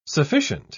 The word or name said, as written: sufficient